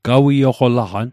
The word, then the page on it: gawioqolaqan: Mi'gmaq